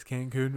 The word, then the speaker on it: Cancun: Just got back from Cancun, not Cancun. – Cancun, what? Cancun. Is that really how you say it? – Yes, Cancun, Mexico.